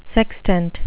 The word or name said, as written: sextant